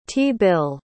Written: t-bill

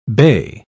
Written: bey

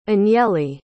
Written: anieli

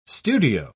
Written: studio

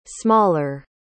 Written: smaller

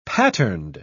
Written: patterned

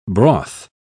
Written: broth

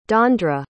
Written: dondre

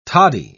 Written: toddy

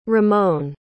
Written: ramon

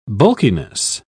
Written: bulkiness

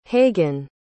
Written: hagan

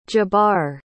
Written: jabbar

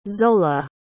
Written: zola

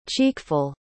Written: cheekful

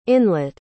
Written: inlet

